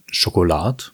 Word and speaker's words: Schokolart: Spricht man das überhaupt so aus? Schokolart? Ja. – Schokolad? Schokolad. – Schokolad. – Also es spricht sich ja so etwas französisch aus.